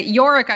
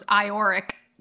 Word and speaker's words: Iorek: Yorick, I always thought was Ioric.